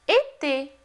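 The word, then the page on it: été: language French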